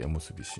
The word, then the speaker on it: OMSB: えー、さらに前日は、えっ、ー、と、 WWWX で、えー、ラッパーのおむすび氏、えー、 OMSB と書いて、おむすび氏の、